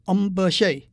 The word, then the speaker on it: hamburger: 他们跟美国一样叫 hamburger，不过发音是 hamburger，那是 hamburger 这个字的法国发音。